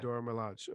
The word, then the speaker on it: Dora Milaje: Yes, actually, you don't know how to pronounce words. Huh? – I can't spell it. If I showed you the spelling, you'd be laughing your ass off right now because that's just not spelled right. – No, you spell like Dora the Explorer. – No, I think you pronounced it correctly.